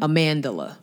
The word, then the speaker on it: Amandla: So Amandla Steinberg. – How do you say her name? – Amandla. – Amandla? – Yeah. For some reason, they added an L to her name.